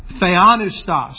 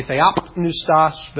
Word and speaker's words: Theopneustos: The Greek word here is Theopneustos, and some people do pronounce the P in that, so they would say Theopneustos, but I've always thought that sounds a little funny.